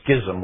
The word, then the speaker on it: schism: I've introduced a new word there called a schism, or some people will pronounce that schism. Can't do that. I'm going to pronounce it a schism with a ch with a hard k sound.